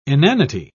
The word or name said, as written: inanity